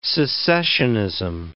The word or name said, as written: secessionism